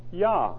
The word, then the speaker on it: Yah: And we're fairly certain about that because there are other scriptures which use the shortened form of the Tetragrammaton, and it's spelled out for us in Hebrew, and the spelling is pronounced Yah.